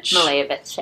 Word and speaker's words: Malevich: Malevich, yeah.